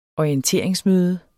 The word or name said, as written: orienteringsmøde